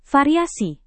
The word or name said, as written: variasi